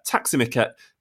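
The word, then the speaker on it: tack så mycket: And then, James, Simon picks you up on your Swedish pronunciation and kind of what you were saying last week. You'll have to remind the listeners, but he said, taximiket is thank you very much, with a little winky face.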